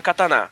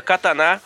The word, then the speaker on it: katana: Pois bem, a língua japonesa não possui sílabas tônicas, fazendo com que a pronúncia katana ou katana seja entendida mais ou menos da mesma forma por um japonês. Na verdade, deve-se falar como se todas as sílabas fossem tônicas, assim como em vovô e vovó.